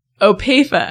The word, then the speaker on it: Opeifa: Opeifa.